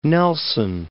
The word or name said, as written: Fort Nelson